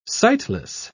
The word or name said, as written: sightless